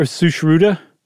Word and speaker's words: Sushruta: Is that how it's pronounced? His name? – Sushrita? – Mm-hmm. – That's what I'm going, or Sushruta? – I mean, it's a U.